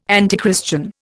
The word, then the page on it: antichristian: Indian